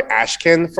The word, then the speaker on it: Ashkan: We always put Ashkan, A-S-H-K-A-N. So they try Ashkan for us. You need to correct them. So if you want them, they call you Ashkan, you need to go A-S-H-C-O-N, then the pronunciation comes correctly.